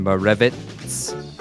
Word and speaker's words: Moravec: I don't know how to say his name. Mozarek? – Ivan Morevitz.